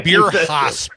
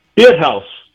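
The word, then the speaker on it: Bierhaus: H-A-U-S. – Beer house. – It's just a house, man.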